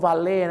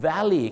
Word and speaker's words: valet: valet นะครับคำนี้ก็ออกเสียงว่า valley